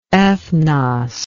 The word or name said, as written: ethnos